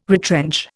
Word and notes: retrench: Indian

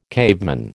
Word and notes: caveman: American English

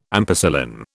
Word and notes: ampicillin: British English

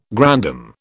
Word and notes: grandam: British English

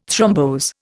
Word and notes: thrombose: Indian

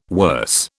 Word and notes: worse: British English

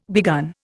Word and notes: begun: Indian

